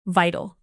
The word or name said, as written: vital